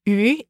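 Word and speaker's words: yu (鱼): And this is because fish is pronounced yu in Chinese, which shares the same pronunciation as surplus.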